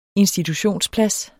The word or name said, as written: institutionsplads